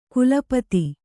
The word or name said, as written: ಕೊಲೆಪಾತ